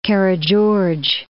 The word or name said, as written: Karageorge